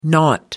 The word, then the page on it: naught: American English